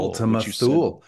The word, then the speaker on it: Ultima Thule: Ultima Thule. Said, yeah, you pronounced that right.